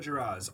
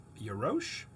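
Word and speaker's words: Jarosz: I'm going to go with Ring Them Bells by Sarah Geraz, although I heard another pronunciation of her name that might be right, which is Yarosh, but it is J-A-R-O-S-C-Z. – Hmm. – O-S-Z. Hold on. O-S-Z.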